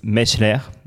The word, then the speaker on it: Meichler: Mattis Meichler, est-ce que je le prononce bien? – Oui, Meichler, ouais, c'est le prononcer à l'alsacienne.